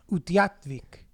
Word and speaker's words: Utqiagvik: Okay, guys, everyone listening, it's spelled U-T-Q-I, which I've never seen without a U, U-T-Q-I-A-G-V-I-K. – Utqiagvik. – I'm not looking at the pronunciation. Utqiagvik. And this is it. Oh, you're pretty close. I actually think you said it right.